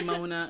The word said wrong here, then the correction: Moana: I was like, yes, it's Moana.